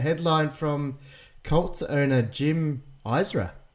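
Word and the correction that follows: Irsay: Or Jim Ursay. Ursay.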